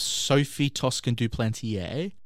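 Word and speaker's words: Sophie Toscan du Plantier: So this show focuses on the murder of Sophie Toscan Duplantier.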